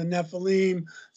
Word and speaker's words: Nephilim: Some people call them the Nephilim.